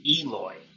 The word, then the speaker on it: Eloy: They almost will go from second to third with Aloy standing on the infield dirt with the ball. – I'm gonna correct you, though. Now that we have the pronunciation guide, it is Eloy.